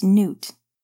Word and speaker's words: Newt: "It's pronounced Newt. Leo Newt. Like the lizard?" – James snorted.